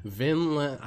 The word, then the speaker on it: Vinland: Vinland Saga. Vinland.